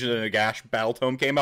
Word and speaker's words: Nagash: It is pronounced Nagash on Rantcast.